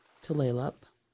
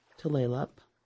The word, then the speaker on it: Tulalip: Tulalip, Tulalip. The name is mispronounced by so many people, but Tulalip is nearest to the right pronunciation for the Snohomish Indian word Duflalop, which means the long bay.